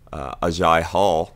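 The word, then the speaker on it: Agiye Hall: In other news, though, former Alabama wide receiver Ajay Hall, that's how you say it, by the way.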